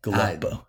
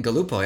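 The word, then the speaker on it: Galuppo: Galupo. Dan Galupo, yep.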